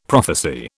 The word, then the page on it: prophecy: British English